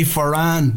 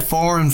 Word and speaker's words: Foran: Johnny, you passed the surname pronunciation test, by the way, because a lot of people in the UK say Foran. You said Foran from the get-go.